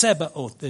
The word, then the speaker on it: Zebaoth: It's Zebaoth is why it's pronounced in Hebrew.